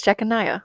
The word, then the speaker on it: Jeconiah: Okay, I was going to say it right. It's Jeconiah.